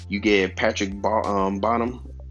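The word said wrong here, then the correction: Baumann: And I'm sorry if I butchered his name. I'm sorry, Bauman. Patrick Bauman.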